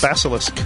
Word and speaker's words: Basilisk: Is that how you say it? Basilisk? – Basilisk.